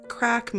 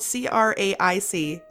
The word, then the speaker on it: craic: Okay, so what does crack mean? And to tell, it's spelled CRAIC, crack.